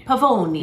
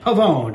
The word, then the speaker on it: Pavoni: Pavoni. – You got to do the hand. Pavoni.